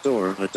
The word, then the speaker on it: Dora: Damn it, I can't say Dora. – Dura. – Wait. – That's actually her Islamic counterpart. – I think he can say it right this time. – You have the map, Dora. Don't ask me. – Close enough.